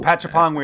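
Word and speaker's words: Apichatpong: But there are plenty other filmmakers in the world who are doing, you know, Joe, however you pronounce his last name, Actapon. – Oh, Patchapon, we asked at the call.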